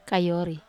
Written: kayori

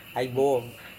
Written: aibuong

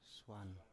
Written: swan

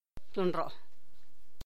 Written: San Jerónimo Tlacochahuaya

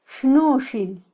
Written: xnuxil

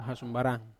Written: hasumbaran

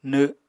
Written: nw7

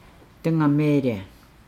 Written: dengameelaa

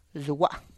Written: zua

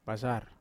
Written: basar

